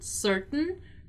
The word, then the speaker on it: certain: Once again here, let's remember the pronunciation of the word certain, right? In which the tain part is not really pronounced, right? Certain, certain, certain.